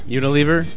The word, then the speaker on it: Unilever: It's owned by Unilever, is that I say that right? Unilever? – Unilever? – Unilever, and they do test on animals.